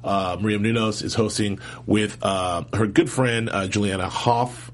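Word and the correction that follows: Hough: I never said that right. – Huff.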